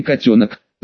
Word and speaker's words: Котенок: «Егор, ты!» Облапывает и трясет меня могучий хирург со смешной фамилией Котенок, с бригадой которого я часто работал. Ударение в фамилии, на последний слог, при ошибке можно было получить в ухо или, как в моем случае, занудную нотацию, но как его звали за глаза, догадаться несложно.